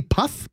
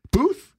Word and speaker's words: Puth: It's actually been overtaken by another music video by Wiz Khalifa and, I don't know how to say this guy's name, Charlie Puth? – Puth. – Puth? – Puth, yeah.